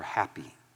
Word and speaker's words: Hapi: And he's going after a god here that is spelled H-A-P-I, pronounced Hapi or happy.